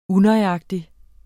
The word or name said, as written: unøjagtig